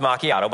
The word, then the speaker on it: macchiato: It's pronounced macchiato, but it's fine.